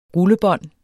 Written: rullebånd

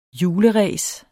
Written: juleræs

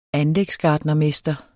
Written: anlægsgartnermester

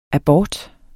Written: abort

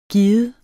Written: given

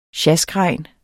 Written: sjaskregn

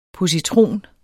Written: positron

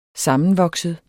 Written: SAMMENVOKSET

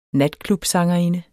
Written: natklubsangerinde

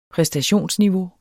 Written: præstationsniveau

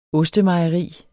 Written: ostemejeri

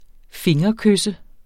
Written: fingerkysse

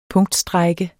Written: punktstrejke

